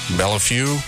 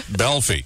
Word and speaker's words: Belleifue: His name is spelled like B-E-L-L-E-I-F-U-E. So how would you say that? Bellefue? – Yeah, that sounds good. – I have no idea. – It's pronounced Belfi, and he hates it when people butcher his name.